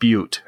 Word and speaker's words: Butte: Yeah, it was it's pronounced Butte, actually.